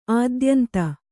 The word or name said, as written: ಅತ್ಯಂತ